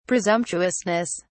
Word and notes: presumptuousness: British English

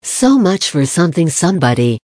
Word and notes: so much for sth sb: American English

